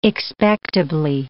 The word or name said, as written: expectably